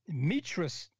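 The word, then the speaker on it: Metra: And so when we look at that, when we reference the seed from the woman's standpoint, we call this the Mitra sperm. So we want to look at the Mitra sperm. Mitra is spelled M-E-T-R-A, S-P-E-R-M.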